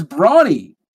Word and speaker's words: Bronny: His name is Bronny. It's spelled like Brony.